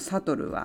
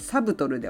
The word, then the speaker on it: subtle: くねこの悲しみのセリフのプローブというのは探りを入れるという意味です keep it subtle さりげなくなねという意味のサトルは B が入ってますけれどもこれはあのサイレントワードの発音しない B なのでサブトルではなくサトルですねでママライリーに尋ねます So how was the first day of school?